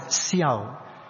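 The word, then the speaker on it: xiao (孝): It's pronounced as xiao.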